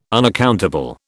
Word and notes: unaccountable: British English

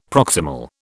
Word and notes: proximal: British English

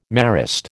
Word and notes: Marist: American English

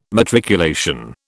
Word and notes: matriculation: British English

